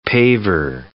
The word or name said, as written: paver